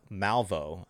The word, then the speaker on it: Malveaux: You know, because here's the thing: we also signed uh, defensive lineman Cameron uh, Malveaux, is how you pronounce his name,